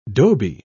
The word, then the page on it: dhobi: British English